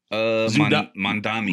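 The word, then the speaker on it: Mamdani: Mondami.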